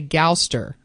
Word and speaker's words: Gouster: It's going to be called The Gouster. I believe that's how you say it. G-O-U-S-T-E-R.